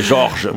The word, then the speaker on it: Jorge: Alors c'est un des prénoms que je n'arrive jamais à prononcer en espagnol, qui est Jorge. Voilà, je peux faire une Jorge. Ouais, Jorge.